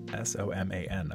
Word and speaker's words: Soman: Soman.